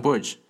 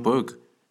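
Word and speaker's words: Berger: 嗯，啊，那他跟我说了一个事儿，就是虽然我们在中文里面把这个人翻译成叫约翰伯格，但他的姓其实应该读成是叫 Burge，John Burge，啊，那不晓得为什么现在大家都开始在叫这个约翰伯格，也不知道是谁开的这个错误的头了，啊，以至于现在让很多人在这个用英文念他的名字的时候，也念成了这个 John Berg 这样，啊，那感觉似乎也是应该改不回来了吧，啊。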